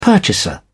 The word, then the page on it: purchaser: British English